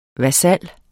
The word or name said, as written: vasal